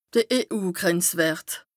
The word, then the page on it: de EU-Grenzwërt: Bas Rhin